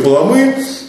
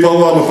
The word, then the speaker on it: Паламы: Первая неделя – торжества православия, вторая неделя – Григория Паламы, или Паламы по-разному ударения, и так в книгах.